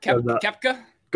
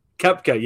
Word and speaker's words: Koepka: I mean, but either way, I mean, not to get too far off is I saw Brooks Koepka, or how you pronounce his name? Brooks. – You talking about the golfer, Koepka? – Golfer. Koepka, yes.